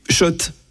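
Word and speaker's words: Schott: Oui, Shot et non, pas Scott. Scott. Ça fait des années qu'on prononce mal la marque.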